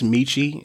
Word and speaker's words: Michi: My jam of the week is Borrowed Time by, I think it's pronounced Michi. It's M-I-C-H-I.